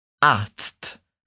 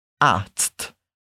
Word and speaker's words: Arzt: Primeiro, nós temos de pronunciar o som do Z, que é como se fosse um TS no português. Ts. E depois, pronunciamos um som de T. T. Por isso, falamos dessa forma. Arzt.